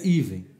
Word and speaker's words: Even: Então amanhã, olha, a Ana Paula está dizendo, já é assim, não fica bravo, mas Even se pronuncia Even, trabalhei lá, empresa maravilhosa, origem hebraica, parente da Cirela. Eu vou falar Evan e pronto, acabou, não vou mudar.